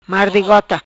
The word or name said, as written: Mardigota